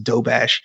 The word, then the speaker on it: Doberge: It looks like it's spelled Doberge, D-O-B-E-R-G-E, but it's pronounced Dobash.